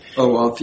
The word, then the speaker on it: OAuth: OAuth, yes.